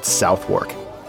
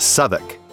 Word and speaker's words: Southwark: If you're reading the screen, you probably think this place is called Southwark, but it's actually pronounced Southwark.